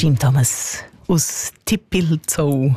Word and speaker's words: Tippilzou: Aber also der Urs, der wahrscheinlich dort wohnt oder einfach sehr verbandelt ist mit dem Ort, hat ihn uns geschrieben, so wie er ihn aussprechen würde. Und er hat wirklich T-I-P-P-I-L-Z-O-U geschrieben. – Also ist Zelt. – Tippelzau. – Ja, Tippelzau. – Tippelzau.